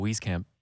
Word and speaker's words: Wieskamp: I just wanted to make you nervous to initiate a Joe Wieskamp I'm always nervous. But I think, I don't know, if the bench is scoring 7 points a night, that's two Joe Wieskamp threes plus a free throw.